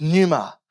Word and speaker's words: pneuma: So the P is silent, it's pneuma.